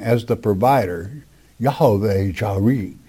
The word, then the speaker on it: Yahweh Jireh: I like to pronounce it correctly, though. It's Yahweh Jireh.